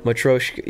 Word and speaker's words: Matryoshka: Matryoshka? – Matryoshka, yeah.